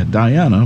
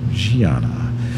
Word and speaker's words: Diana: That selection titled Gianna, which actually in English pronunciation would be Diana, but because of Wayne Shorter's Brazilian influence, the title is pronounced Gianna.